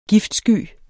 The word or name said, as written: giftsky